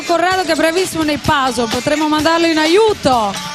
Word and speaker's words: puzzle: Si scrive puzzle e si pronuncia puzzle.